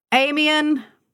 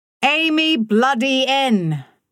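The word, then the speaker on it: Amiens: Amian! He pronounced it properly, the S silent. Kate's father had named their property for the first war battle in France, where he'd lost so many friends. Amy bloody N!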